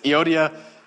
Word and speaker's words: Euodia: Not a big deal, but like, you're not pronouncing them quite right. And I was like, great, an hour of my life just gone, never to be recovered. So that's the best I can do based on what I studied and what Jeremy told me is Euodia and Syntyche.